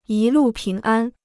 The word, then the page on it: 一路平安: yī lù píng ān